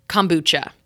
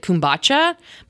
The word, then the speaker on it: kombucha: I definitely was one of the people that had to look up the correct pronunciation because I always want to call it kombucha, but that's not how you say it. It is kombucha.